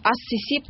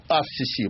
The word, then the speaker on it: ас-Сиси: Наш герой, Абдель-Фатах Саид Хусейн Халил ас-Сиси, а именно ас-Сиси произносится по-русски, потому что по правилам арабской филологии... – Ну, по-арабски ас-Сиси, потому что «с» — это солнечный харф, артикль редуцируется, харф удвояется. Правильно, ас-Сиси.